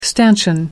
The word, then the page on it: stanchion: American English